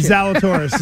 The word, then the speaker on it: Zalatoris: Zalatoris is right. – Zalatoris. – Yes. It's like halitosis.